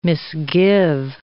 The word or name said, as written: misgive